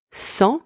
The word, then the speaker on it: cent: First one, cent, spelled C-E-N-T, means 100. In this word, the letter C is pronounced S. The letters E and N together are pronounced en, and the final consonant T is silent. Second word, sang, spelled S-A-N-G, means blood.